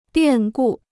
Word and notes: 变故: biàn gù

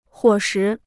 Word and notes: 伙食: huǒ shí

